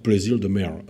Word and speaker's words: Plaisir de Merle: Et je vous ai parlé aussi de Plaisir de Merle, qu'on prononce là-bas Plaisir de Merle. Hein, bon, à peu près comme cela.